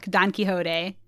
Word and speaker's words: Don Quixote: He signs up voluntarily for a 6-month stay to, quit smoking and to read a book, Don Quixote.